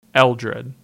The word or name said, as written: Eldred